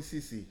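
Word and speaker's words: Ceci: Cody Cece. – Cece, okay. I thought it was a Z somewhere. Anyways. – No, Cody Cece.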